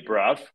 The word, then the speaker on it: Bruff: Well, the woman I'm supporting, her name is Kelly Bruff, which rhymes with tough.